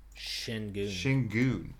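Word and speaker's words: Şengün: Sengun? Is that their? – Sengun.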